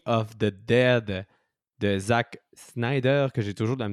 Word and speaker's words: Snyder: The non, je euh, pense que c'est Snyder.